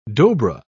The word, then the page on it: dobra: British English